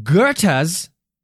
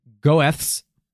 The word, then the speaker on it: Goethe: In Goethe's, spelt the stupid German way, which should be pronounced Goethe's, but it's pronounced Goethe, right? – Right. – Goethe. G-O-E, Goethe, drama, and many subsequent versions of the story, Mephistopheles helps Faust seduce a beautiful and innocent girl, usually named Gretchen.